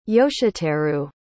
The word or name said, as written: yoshiteru